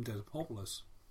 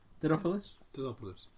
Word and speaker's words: Dedopulos: It's a bit roguish, but it's been by a chap called Tim Didopoulos. I've got his name wrong. – Didopoulos.